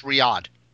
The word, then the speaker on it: Riyadh: They did an event in Riyadh. I don't know if I'm pronouncing that correctly. – No, you got it on the money. It's Riyadh.